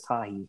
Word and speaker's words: Tajae: pronounces his name as Tahi. Yeah, it does. Tahi moment is his Twitter.